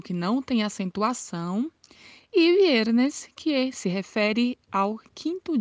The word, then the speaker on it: viernes: Esta pronúncia de viernes é uma pronúncia um pouco diferenciada, porque no espanhol o V tem um som de B. Então pronunciamos não viernes, mas pronunciamos viernes, como se quiséssemos falar a letra B, como se quiséssemos falar viernes, mas não é um B muito fechado, é viernes.